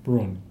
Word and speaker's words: Bruhn: And you mentioned that Professor Bruhn? – Bruhn. – And how do you spell that? – B-R-U-H-N.